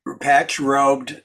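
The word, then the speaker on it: patch-robed: We patch broad, bro. How do you say that? Patch broad. – Patch robed mendicants.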